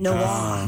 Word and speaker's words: Noir: Noir.